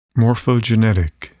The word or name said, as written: morphogenetic